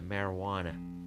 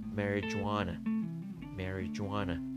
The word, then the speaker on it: marijuana: ماریجوانا که در انگلیسی دو تا تلفظ عمده داره در حقیقت یکیش خیلی عمده است ولی اون تلفظی که بسیار بسیار شایع هست در زبان انگلیسی مروانه مروانه یعنی همون ماریجوانا می نویسن ولی ماریجوانا شایع نیست که بگن میگن مروانه و یا مری جوانه